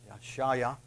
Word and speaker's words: Yeshaya: Probably you recognize it more by the name Isaiah, but Yeshaya would probably be the correct pronunciation, which actually means Jehovah or Yahweh has saved, which is much like Joshua, Yeshua.